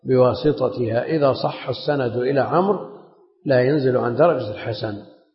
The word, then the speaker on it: عمرو: قال وعن عمرو بن شعيب، بعض طلاب العلم ينطقون عمرو بالواو والضم ومدريش، هذا الكلام مو صحيح هنا. وعن عمرو بن، والواو هذه يؤتى بها للتفريق بين عمرو وعمر، وإلا تأتي عليه الحركات كلها. اسمعوا، يتحدث عن شخص عمرو، ما بصحيح، ما بعمرو وعمر. رأيت عمرا، مررت بعمر، فالواو إنما لا تنطق، إنما يؤتى بها للتفريق بينه وبين عمر.